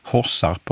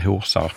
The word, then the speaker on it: Horsarp: Då kan vi börja med Horsarp och Horshult, som det säkert uttalas, och inte Horsarp och Horshult.